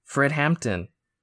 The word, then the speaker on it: Fred Hampton: Fred Hampton.